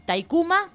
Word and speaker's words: Taykhuma: Dijiste, Capilla del Monte, Casa Taikuna. – Taikuma. – Taikuma. – Casa Taikuma, que también lo pueden buscar, se escribe T-A-Y-K. H-U-M-A, lo pueden buscar en Facebook.